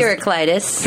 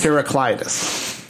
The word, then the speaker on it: Heraclitus: But what is the correct way to pronounce it? Heraclitus. – Heraclitus.